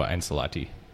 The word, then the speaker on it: Ancelotti: Zinedine Zidane, who is Real's current coach, was an assistant under Carlo Ancelotti. I'm screwing up the pronunciation on that. – No, you didn't, actually.